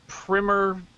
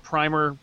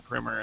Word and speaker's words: Primer: I'm going to go with the clever way, which is to say I'm going to take Shane Carruth's cult time travel movie Primer. You might call it Primer. That's the wrong way to pronounce it, but that's fine, whatever.